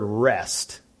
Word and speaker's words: wrest: The American Standard Version uses the word wrest, spelled with a W, W-R-E-S-T.